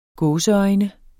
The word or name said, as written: gåseøjne